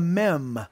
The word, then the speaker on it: mem: All right, our next letter is the mem. It's M-E-M, and it's a one-syllable word, so it's trying to get you to express the M sound.